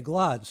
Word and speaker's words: Glaude: We're spending the hour with, and forgive me, Professor Glaude. It's Glaude, not Glaude. I put the U in there, and that probably happens to you a lot. I don't want to be a clod and not say Glaude.